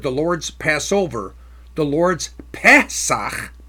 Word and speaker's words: Pesach: Now the Hebrew word here is Pesach. Pesach. The Strong's number is 6453. The Pe is in short E sound as in bed. Pe. Pesach.